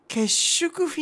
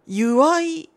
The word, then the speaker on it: 祝い: これ何ですかね、結縮フィナンシェの日って読むんですかね、祝いって読むんだ、祝いフィナンシェの日、名古屋の洋菓子店が決めたみたいです。